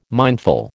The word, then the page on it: mindful: American English